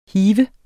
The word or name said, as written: hive